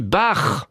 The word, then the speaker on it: Bach: Alors, je me demandais, petite parenthèse, si ça vous agaçait que je prononce Bach. Et cet auditeur, auditrice, une auditrice nous dit Bach, surtout ne prononcez pas autrement.